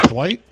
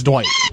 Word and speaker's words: Dwight: My name is Dwight. – Name? – My name is Dwight. – How spell it? – D-W-I-G-H-T.